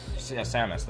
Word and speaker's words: Samus: Samus? It's like she's in a suit in space and has like a... – Oh, Samus. – Samus, there we go.